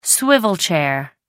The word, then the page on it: swivel chair: American English